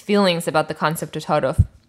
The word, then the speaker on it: ta'arof: Ta'aruf. – Exactly. So formally, the word is pronounced ta'aruf with the stop in the middle of the word, ta'aruf. But colloquially, it's simply pronounced ta'aruf.